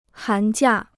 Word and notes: 寒假: hán jià

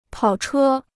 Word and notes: 跑车: pǎo chē

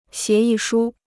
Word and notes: 协议书: xié yì shū

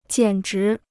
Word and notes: 简直: jiǎn zhí

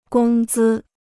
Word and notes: 工资: gōng zī

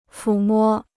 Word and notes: 抚摸: fǔ mō

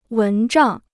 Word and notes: 蚊帐: wén zhàng

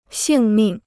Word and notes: 性命: xìng mìng

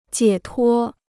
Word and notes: 解脱: jiě tuō

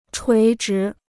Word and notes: 垂直: chuí zhí

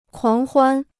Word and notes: 狂欢: kuáng huān

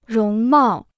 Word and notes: 容貌: róng mào

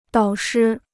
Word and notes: 导师: dǎo shī

